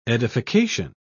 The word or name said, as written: edification